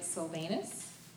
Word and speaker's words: Silvanus: By Silvanus, did I say that right? – Sure.